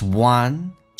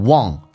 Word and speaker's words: one: successful man is one, 这个是前鼻音 w 加个 an, 我们很多人发成 one, 发成后鼻音 one